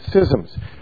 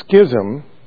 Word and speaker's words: schism: But over the choice of that one word, infallible, there have been great schisms. By the way, we always pronounce the word schism until you go to seminary, where they teach you that the right way to pronounce it is schism.